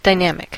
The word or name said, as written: dynamic